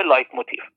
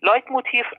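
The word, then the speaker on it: Leitmotiv: لایت موتیف، البته من جایی دیدم خیلی بعد اینو تلفظ میکنم میگن موتیف. موتیف کلمه انگلیسیه به معنی انگیزه که اصلا خیلی فرق میکنه. این موتیف کلمه آلمانیه به معنی شبه جمله است.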